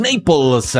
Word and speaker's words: Naples: Un saludo muy grande y de por medio va a haber un clásico en Naples. Así se pronuncia.